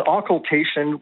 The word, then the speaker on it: occultation: Is an occultation? Am I pronouncing that correctly? – It's an occultation, which sounds like, I don't know, something out of a horror movie.